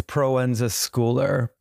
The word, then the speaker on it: Proenza Schouler: It's Proenza Schouler, like school.